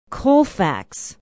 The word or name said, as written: colfax